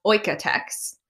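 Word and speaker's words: OEKO-TEX: So I recently learned that it's pronounced Oikotex, Okay.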